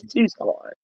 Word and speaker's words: Tucson: It's silent. The sea is silent. – The sea is silent. – It is. Tucson. – Okay, it's Tucson.